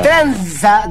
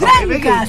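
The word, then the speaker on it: trança: Tranza, digo yo, no sé. Si hay algún brasilero... No. – Tranca, se lee tranca. – No, se pronuncia patita. – Tranza. Dice acá.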